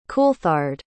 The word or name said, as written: coulthard